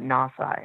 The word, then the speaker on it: NAWSA: People just pronounce that acronym and they call it NAWSA, N-A-W-S-A.